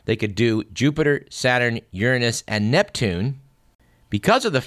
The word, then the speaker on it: Uranus: It was 25 years ago this week that the Voyager 2 spacecraft whipped out to the planet Uranus. And yes, that is the correct pronunciation.